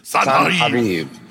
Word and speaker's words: Sennacherib: And you're like, no, it's how do you guys say it again? – In Hebrew, it's Sennacherib. – Sennacherib.